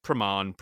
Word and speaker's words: Praman: Correct me if I'm wrong, but it's spelled P-R-A-M-A-N. So Praman, you know, Praman.